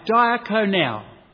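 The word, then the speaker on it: diakonou (διακονεῖν): The word "serve" there in the Greek is a verb which is pronounced diakonou, diakonou.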